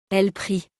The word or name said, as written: elles prient